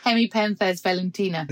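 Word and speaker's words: Hemipenthes velutina: You said that to me about six times before we started, and I still pronounced it wrong, didn't I? – Henopemphis velutina. – There we go.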